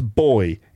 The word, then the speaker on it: buoy: Well, that's a buoy, pronounced boy in English. Which is why whenever you're, you know, at the seaside or looking at a lake with your dad would always go, oh, look, there's a boy in the water. What? A boy in the water? Oh, I see. Right. So a boy in British English. And it's just pronounced all wrong and weird in American English, like buoy.